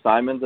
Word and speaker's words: Simons: I don't think it's immediately going to be for Simmons or anything, or Simons, I think is how you pronounce the name.